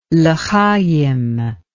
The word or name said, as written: lehayim